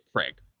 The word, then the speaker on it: Kreg: Look, if you want a name to put down, you can say Craig. – Do you say Craig? – Craig. – How do you spell that? K-R-E-G. – There you go. – K-R-E-G.